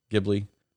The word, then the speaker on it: Ghibli: Ghibli? – Yes, you are correct.